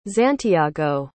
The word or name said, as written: xantiago